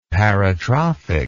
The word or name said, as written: paratrophic